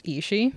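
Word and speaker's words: Aos Sí: Another myth that is within Samhain is the belief that Ishi, which is A-O-S-S-I, pronounced Ishi, would be present.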